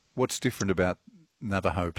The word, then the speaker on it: Navajo: Oh, Navajo.